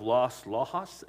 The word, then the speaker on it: Las Lajas: That is the Shrine of Our Lady of Las Lajas, L-A-J-A-S, is how you say that, located in Colombia.